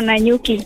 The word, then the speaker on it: Nanyuki: Niko na Nyuki. – Uko na Nyuki. Habari ya Nyuki? – Si venye unaisema. – Nilijua tu naisema vibaya. – Tuko salama. – Hebu nipatie pronunciation correct. Nanyuki. – Yeah, Nanyuki.